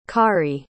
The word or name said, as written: khari